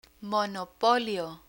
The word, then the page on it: monopolio: Spanish